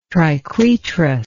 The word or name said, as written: triquetrous